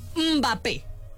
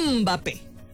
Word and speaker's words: Mbappé: Otro, Mbappé. Mbappé. No, Mbappé, porque luego si no lo digo bien, no se escucha bien el nombre.